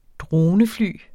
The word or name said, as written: dronefly